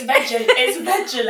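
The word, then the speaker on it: Veja: I only recently learned how to pronounce that. – Oh, did I pronounce it properly? – I think so. – Oh, good. – I was going Vedja. Not so chic. It's Vedja leather.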